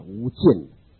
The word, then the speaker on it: 无间: 把整个空间都塞满，所以无间哦。所以时间是没有间断，空间也没有间断，痛苦也没有间断，所以称为无间。这个间是间断那个间，应该读作四声啊，无间，无间地狱应该按那个读。